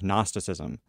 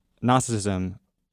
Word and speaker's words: Gnosticism: But this is the stuff that reminded me so much of Gnosticism. Now, Gnosticism, is like early Christian mysticism. – I know what it is. – Okay, well, never mind. You pronounce it like gnocchi.